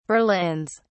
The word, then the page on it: berlins: British English